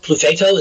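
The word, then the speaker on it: Clufetos: We had a lot of fun, you know, but at the same time, and Tommy Clufostis, or however the fuck you pronounce his name. – Clufato, is that it? – Yeah. – Okay, thank you.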